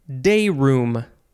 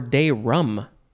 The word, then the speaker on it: day room: The next word is day room. Day room or day rum. I guess some people say day rum. But it is spelled like room, R-O-O-M.